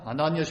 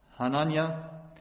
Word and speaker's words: Hananja: Denn dann trat Hanania spricht man es aus, nehme ich an, H-A-N-A-N-J-A, Hanania, ein Prophet unter dem Volk, trat vor, nahm Jeremia das Joch vom Nacken herunter und zerbrach es.